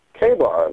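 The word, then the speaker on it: KA-BAR: K-Bar? – K-Bar, that's right.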